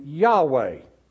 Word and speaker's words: Yahweh: You have your Y, your H, your W, and your H, and then you just add the vowels and you get the name Yahweh. And so that is the correct, as best as we know this side of eternity, that is the correct pronunciation of God's name.